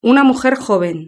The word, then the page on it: una mujer joven: European Spanish